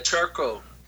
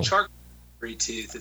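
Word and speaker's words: Charcot-Marie-Tooth: Charcot-Marie Tooth Disease. – Oh, that's pretty good. Pretty close. Yes, charcoal. – Charcoal. – Charcot-Marie Tooth.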